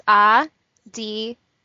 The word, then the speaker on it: Ariana: There is actually no R roll. I'll give you a little lesson right now on how to say my name. it's like pronouncing a D instead of an R. So it's Ariana.